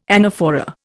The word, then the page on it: anaphora: Indian